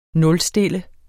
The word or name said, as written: nulstille